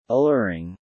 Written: alluring

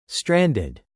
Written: stranded

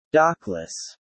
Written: dockless

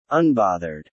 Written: unbothered

